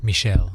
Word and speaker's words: Michel: McHale's. McHale? – Michel. – Don, John Michel's. that's how you say his name because he's French.